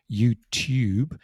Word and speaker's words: YouTube: So for somebody from America, there's no difference between two, T-W-O, two, T-O-O, and then Tuesday or YouTube. – That's a good one as well. – When they have this T-U, they pronounce it like two, like the number two, but British people, I don't know why, we have this y sound. So we say YouTube and Tuesday, this y sound.